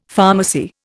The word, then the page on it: pharmacy: Indian